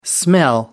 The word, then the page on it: smell: American English